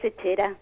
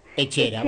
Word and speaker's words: Etxera: Quizás pronuncié mal el nombre del colectivo. – Sí, es Echera. – Echera, bueno. Echera en euskera, que es la lengua del pueblo vasco, quiere decir casa, a casa.